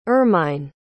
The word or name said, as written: irmine